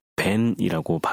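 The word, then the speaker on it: 벤이라고 (בן): 특별히 우리가 여기서 이제 한 가지 주목해야 할 단어가 있는데 히브리어로 아들이라고 하는 단어는 벤이라고 발음을 합니다.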